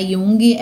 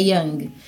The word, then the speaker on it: Young: Não é Jung, é Yang Uhum.